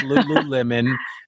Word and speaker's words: Lululemon: Because I mean, trust me, there are people listening to this podcast that have called it, oh, I didn't know it was Lululemon. I thought it was Lululemon. No, it is Lululemon.